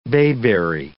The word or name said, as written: bayberry